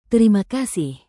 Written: terima kasih

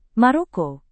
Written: Maroko

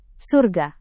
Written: surga

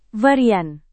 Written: varian

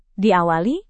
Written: diawali

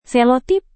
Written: selotip

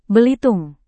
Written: Belitung

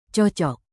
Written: cocok

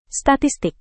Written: statistik

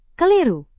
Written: keliru

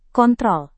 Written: kontrol

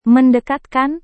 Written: mendekatkan

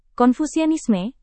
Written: konfusianisme

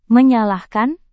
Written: menyalahkan

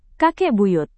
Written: kakek buyut

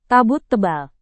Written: kabut tebal

